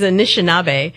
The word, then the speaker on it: Anishinaabe: The word is Anishinaabe.